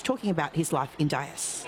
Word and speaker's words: Dyess: Raised in the town of, I'm going to say Dyess. – Dyess. – Dyess. – That's pronounced, yeah.